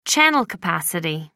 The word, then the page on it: channel capacity: American English